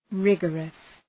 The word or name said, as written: rigorous